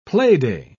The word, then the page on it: playday: British English